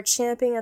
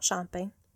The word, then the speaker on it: champing: Generally, a business plan is considered necessary to plan and grow your business, but not too many people are champing at the bit to sit down and write one. I said that right, by the way. It's champing at the bit, not chomping.